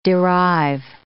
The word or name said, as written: derive